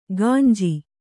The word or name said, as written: ಗಂಜಿ